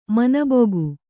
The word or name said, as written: ಮೀನುಬೆಕ್ಕು